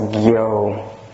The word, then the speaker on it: gyo: Usually it's pronounced gyo, gyo. Like you can find it in like gyoji, which we translate as continuous practice.